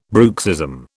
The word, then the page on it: bruxism: British English